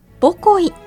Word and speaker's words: 母恋: 母に恋と書いて母恋と読む母恋駅は、毎年 5 月の母の日に合わせて販売する母の日記念乗車券を求めて全国各地から多くの人が訪れます。かつては駅の裏まで海が迫り、ホッキ貝がよく取れたという母恋駅。